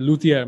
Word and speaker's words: luthier: And luthier, am I pronouncing that right? – Yep, that's absolutely right.